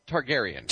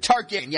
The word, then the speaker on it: Targaryen: Targaryen. – Targaryen, yeah. It's not a name that sticks with me, but the Targaryens.